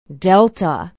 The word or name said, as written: delta